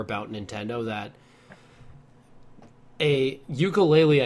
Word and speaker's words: Yooka-Laylee: Yooka-Laylee, yeah.